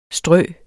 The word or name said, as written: strø